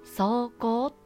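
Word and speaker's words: 霜降: 霜が降ると書いて走行と読むので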